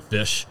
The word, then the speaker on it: Fysh: I say it like that, fish. F-Y-S-H. And Fish. – And then,